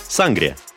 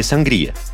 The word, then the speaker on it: сангрия: Испанский национальный напиток – сангрия или сангрия. В русских словарях его, к сожалению, пока нет, но в испанском ударение на «и».